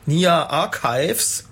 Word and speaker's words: Nia Archives: das war Vertical von Nia Archives.